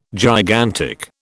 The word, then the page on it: gigantic: British English